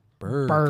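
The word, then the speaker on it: Br'er: How do you pronounce that? B-R apostrophe E-R. – Briere? – Briere? – Burr? – Burr.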